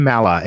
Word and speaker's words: Maller: Ben Maller, M-A-L-L-E-R.